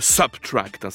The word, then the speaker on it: SBTRKT: C'est un compositeur et interprète de musique électronique qui apparaît sur scène derrière d'étranges masques de bois sous le nom de Subtract. Ça s'écrit SBTRKT, voilà.